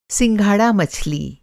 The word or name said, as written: सिंघाड़ा मछली